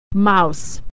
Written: mouse

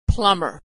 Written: plumber

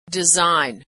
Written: design

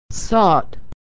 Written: sought

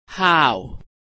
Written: how

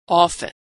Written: often

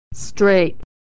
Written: straight

